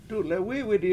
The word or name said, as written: dule7wI7widi